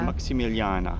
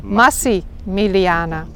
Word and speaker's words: Massimiliana: Maximiliana. – Nee, niet Maxi. Massimiliana. Vandaar dat dit fort Forte Massimiliana heet.